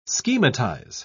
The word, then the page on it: schematize: British English